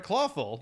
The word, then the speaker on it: Clawful: Clawful.